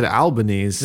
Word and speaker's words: Albanese: Lindsay said Albanese.